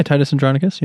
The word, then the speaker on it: Titus Andronicus: Yeah, Titus Andronicus, yeah.